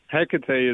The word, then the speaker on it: Hecate: Hecate is how we pronounce it here.